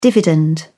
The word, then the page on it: dividend: British English